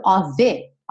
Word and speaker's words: of: Esse of, que se escreve OF, sempre, sempre, sempre vai ser pronunciado OV, ok?